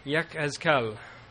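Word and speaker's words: Yek-Ezkel (יחזקאל): As I pronounced at my opening, his name was pronounced in the Hebrew, Yek-Ezkel.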